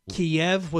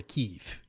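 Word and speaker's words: Kyiv: They've also bombed a shopping mall in Kiev, which, by the way, over the weekend I learned, why it's pronounced Kiev. Kiev was the Russian pronunciation. The Ukrainian language, it's a little different than it comes out more Kiev.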